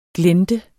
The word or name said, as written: glente